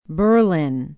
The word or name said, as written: berlin